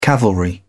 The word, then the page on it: cavalry: British English